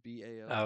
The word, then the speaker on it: Bao: Is it Bao? Is that how you pronounce B A O? – Oh yeah, Bao.